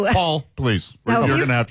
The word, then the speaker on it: Poll: P-O, it's pronounced Paul, but it was spelled P-O-L-L, as in pole.